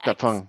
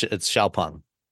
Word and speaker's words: XPeng: It's spelled Xpeng, but it's Xiaopeng.